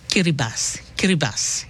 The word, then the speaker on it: Kiribati: A while ago, him pronounce the same Kiribati. Yeah, so the TI in Kiribati him pronounce as SI.